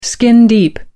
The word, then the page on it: skin-deep: American English